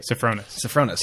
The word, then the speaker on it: Sofronas: Was it Sonnefrost? – Yeah, Sonnefrost. Okay. And I hope we're saying that right. – Sofronas.